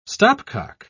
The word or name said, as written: stopcock